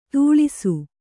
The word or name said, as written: ಧೂಳಿಸು